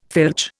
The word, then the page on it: filch: Indian